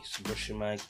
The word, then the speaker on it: Sabushimike: I am your host, Ori Subushimike. Oh, it's spelled A-U-R-Y. Subushimike is spelled S-A-B-U-S-H-I-M-I-K-E.